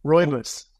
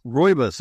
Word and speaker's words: Rooibos: Rooibos. – Rooibos.